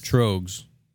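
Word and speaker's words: Tröegs: We got Grand Cacao from, how do you say that? Is it Trogs? – Trogs. – Yeah, Trogs, and they're in Pennsylvania, I believe.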